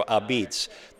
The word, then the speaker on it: apizza: The important thing to know about this style, and I referred to this in the intro, Abitz, the way they pronounce the word A-P-I-Z-Z-A. From what I read your book, I gathered the preposition was la pizza, melded it together into Abitz.